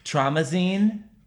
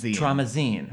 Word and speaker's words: Traumazine: It's Traumatized? – Traumazine. – Yes, Traumazine. – Traumazine. – Mamazine. Like Magazine.